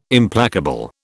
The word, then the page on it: Implacable: British English